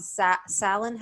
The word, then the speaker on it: Samhain: You call it Samhain. How do you spell it? – Samhain. It's Gaelic, so it's pronounced completely different than the way it sounds.